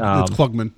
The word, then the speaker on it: Klugman: It's Klugman, yeah.